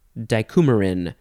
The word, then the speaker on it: dicoumarin: Dicoumarin. D-I-C-O-U-M-A-R-I-N.